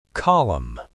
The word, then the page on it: column: American English